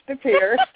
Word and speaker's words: De Pere: How do you say that? I'm like, how do you, De Pere, Wisconsin, right? – Yes, it's De Pere.